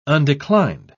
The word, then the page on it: undeclined: British English